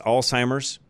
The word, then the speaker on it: Alzheimer's: So, and some of you probably also noticed that I try to pronounce Alzheimer's with the Z as much as I can, because it's not a T. You'll hear a lot of people, and I do my very best to make sure that I pronounce that correctly. Sort of a pet peeve of mine, I guess. I hear so many people call it Alzheimer's, and it's not. So, again, sorry that I overemphasize that probably at times.